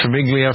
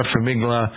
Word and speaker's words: Farmiga: Is it Farmiglia? Farmiga.